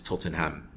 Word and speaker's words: Tottenham: It's Tottenham. You know, even my wife, when I've tried to say to her, just you don't pronounce the EN, it's Tottenham.